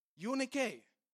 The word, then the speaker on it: Eunike: Eunike. You means good. Nike means victory. Nike. Nike means victory. That is how it is pronounced, by the way, if you didn't know that.